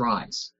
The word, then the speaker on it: Rhys: Apparently, you'll be playing as both Fiona, who's a con artist, and Rhys. I think that's how you pronounce his name. It's spelled R-H-Y-S. – I think it's Rise.